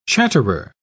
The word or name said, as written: chatterer